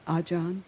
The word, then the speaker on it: Ajahn: Ajahn.